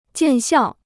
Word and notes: 见效: jiàn xiào

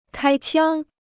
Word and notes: 开枪: kāi qiāng

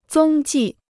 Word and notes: 踪迹: zōng jì

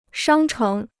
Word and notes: 商城: shāng chéng

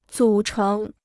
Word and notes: 组成: zǔ chéng

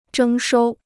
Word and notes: 征收: zhēng shōu

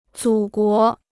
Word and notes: 祖国: zǔ guó

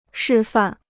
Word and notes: 示范: shì fàn

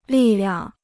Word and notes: 力量: lì liang

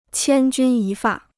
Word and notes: 千钧一发: qiān jūn yī fà

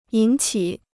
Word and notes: 引起: yǐn qǐ

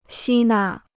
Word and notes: 吸纳: xī nà

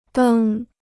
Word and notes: 登: dēng

